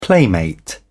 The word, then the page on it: playmate: British English